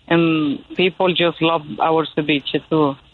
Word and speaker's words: ceviche: Tell us about the shrimp ceviche. Did I say that right? Probably not. – Yes, shrimp ceviche.